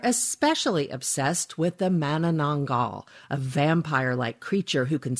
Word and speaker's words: Manananggal: And I got to say, your pronunciation of Manananggal is quite impressive.